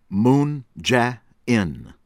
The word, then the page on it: Moon Jae-In: American English; from South Korea